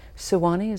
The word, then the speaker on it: Sewanee: His education has been in these ways, a Bachelor of Science in Forestry in 1968 from the University of the South in Sewanee, is that the pronunciation? – Right.